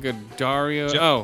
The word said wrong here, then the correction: Giancarlo Esposito: Giancarlo Esposito. – God damn. Giancarlo Esposito.